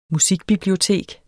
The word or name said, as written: musikbibliotek